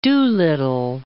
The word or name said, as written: Doolittle